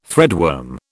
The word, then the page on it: threadworm: British English